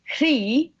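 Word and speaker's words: Croí: Is that how you say it? – Yeah, we all say it differently. It's meant to be CRI in Gaelic, like Irish Gaelic, but we end up kind of saying CRI or CRA or, yeah, but it's meant to be CRI.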